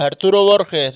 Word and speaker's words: Arturo Borges: Pues Arturo Borges, de la Asociación para la Defensa de la Sanidad Pública de Canarias.